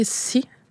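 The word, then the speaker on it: Assi: Ça s'écrit A-S-S-I, c'est le mot pour terre dans la langue Inuimon. Mais chez moi, dans le dialecte, on va le prononcer si. Fait que c'est vraiment comme, c'est comme le mot en anglais pour la mer,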